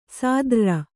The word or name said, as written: ಸಾದ್ರ್ರ